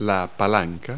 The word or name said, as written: lever